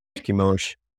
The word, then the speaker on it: Chemosh: Is it Chemosh or Chemosh? – I hear it both ways. I think you're fine. Either way. Tomato, tomato. Chemosh. – Okay. – Chemosh would probably be how it in Hebrew, but the Chemosh is how I often say it myself. Chemosh in Akkadian and stuff like that.